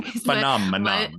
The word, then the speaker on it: phenomenon: And so when you're standing in the ocean, you are literally stepping into this weird magical phenomenon. Phenomenon. That's a hard word. – You said it right, but you made it sound wrong. Phenomenon. – My mind went, that's a weird word, as I was saying.